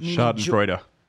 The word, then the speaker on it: Schadenfreude: It's called Schadenfreude. Schaden meaning damage or harm, and Freud or Freud, maybe Schadenfreude is how you say it. – Schadenfreude.